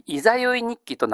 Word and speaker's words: 十六夜日記: じゃあ、十六夜日記と書いたら、これ読み方どうなるんですか？はい。いざよい日記となりますね。